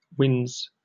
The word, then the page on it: wins: British English